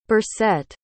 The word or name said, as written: Burcet